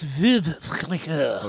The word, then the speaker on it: Veuve Clicquot: I do know Veuve Clicquot, and if I'm saying it correctly, because I'm from Jersey and you're from Philly, so all the French. – I believe it's Veuve Clicquot.